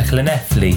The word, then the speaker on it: Llanelli: And then a couple of days ago, we went to Llanelli. Have I pronounced that right? – I think you have, yeah.